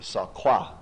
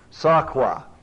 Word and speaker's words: tsedaqah: The best I can say is it's saqua. Probably saqua in the Hebrew. T-S-E-D-A-Q-A-H. Write it down and see if you can pronounce it.